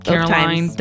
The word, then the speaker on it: Caroline: Caroline, do you like to dine finely? – I do, because it rhymes with my name. – Caroline, dine fine, drinking water, drinking wine.